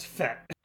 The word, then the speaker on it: fête: It's a French word, and it's pronounced fête.